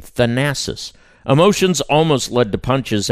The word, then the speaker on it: Thanasis: Thanasis.